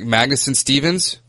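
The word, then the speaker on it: Magnuson-Stevens: Should we go into, if I could pronounce it right, Magnuson Stevens? – Yeah, that's correct.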